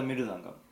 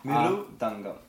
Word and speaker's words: mridangam: It's called a mridangam. – Yeah, there's like five syllables in there. – Mridangam. – Mridangam.